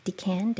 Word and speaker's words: decan: And I thought about the third phase or the third decan. I don't know how you say it. Decan. – Yeah. – Decan of Aquarius.